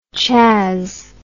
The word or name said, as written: chas